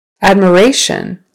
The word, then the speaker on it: admiration: Admiration. Admiration. Third syllable stress.